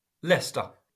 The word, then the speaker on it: Leicester: Leicester.